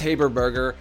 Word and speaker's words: Haberberger: Please join me in welcoming Liz Haberberger to the show.